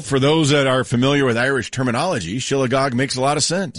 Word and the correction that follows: shillelagh: Isn't it pronounced like shillelagh? – Yeah, whatever.